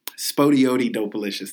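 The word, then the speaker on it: SpottieOttieDopaliscious: Spotty Oppy Dopalicious, from the, I probably mispronounced that, from Spotty Oddy Dopalicious, that's it, from Aquemini.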